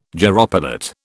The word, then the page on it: gyropilot: British English